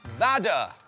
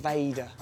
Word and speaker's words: Vader: Vada. Vada. – But I think it's pronounced Vader.